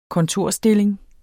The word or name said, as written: kontorstilling